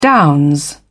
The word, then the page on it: downs: British English